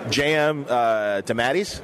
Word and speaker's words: J.M. DeMatteis: so I'm going to say it wrong first, and then Mike will correct me. J.M. DeMatteis? – That's what he says. – Okay, fine. Then that's what I'm going for.